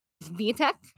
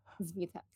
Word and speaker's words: Svitek: Um, particularly, um, the article that I'm talking about was written by a reporter called Patrick Steve uh, Svitek. Um, I looked up how to say his name. Um, Svitek. Um, anyways, uh, but he talked like Greg Abbott in his uh, speech talked about five emergency items.